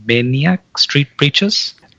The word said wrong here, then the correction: Manic Street Preachers: Oh, Manic Street Preachers.